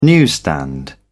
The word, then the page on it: newsstand: British English